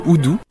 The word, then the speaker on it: udu: notamment le oudu, ça s'écrit U-D-U.